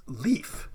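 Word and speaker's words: līf: Life in Old English would have been pronounced leaf.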